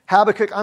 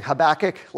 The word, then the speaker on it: Habakkuk: So, Habakkuk, I'm probably going to goof this up and say, because I grew up saying Habakkuk, like most of you did. And then I learned Hebrew in seminary, and I think the proper pronunciation is something more like Habakkuk.